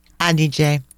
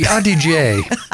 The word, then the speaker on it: Adige: Adige. That's not an easy one to pronounce. – Okay, so the Adige.